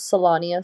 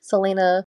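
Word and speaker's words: Celaena: In my head, I was reading her name as Selania Sardothian. So that's kind of similar, but not quite correct. Selena Sardothian is the correct pronunciation, apparently, according to the pronunciation guide.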